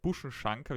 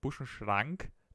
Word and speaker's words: Buschenschank: Zum Beispiel Buschenschank, habe ich immer gedacht, Buschenschrank, dass es so basically wie ein Schrank ist.